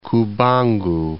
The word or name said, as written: Cubango